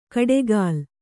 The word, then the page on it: ಕಡೆಗಾಲ್: kaḍegāl